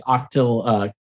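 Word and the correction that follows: Xochitl: Gomez. Oh, it's Zoshi.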